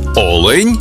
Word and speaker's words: олень: в однині, і в множині наголос падає на перший склад – олень та олені.